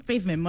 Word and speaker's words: Pavement: Pavement, molt ben pronunciat, sí.